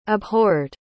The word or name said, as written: abhorred